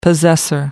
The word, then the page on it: possessor: American English